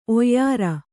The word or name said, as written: ಓಯಾರ